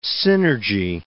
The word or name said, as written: synergy